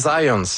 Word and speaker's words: Zajonc: c'est Zajonc. – Ouais, OK. – J'ai-tu pas dit Zajonc? – Vous avez dit plus Zajonc, mais... – J'ai prononcé un petit peu à l'anglais. J'ai prononcé mon polonais à l'anglais.